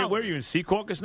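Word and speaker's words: Secaucus: I call it Sea Caucus. – Sea Caucus. Well, you're a New Jersey person, so you would know.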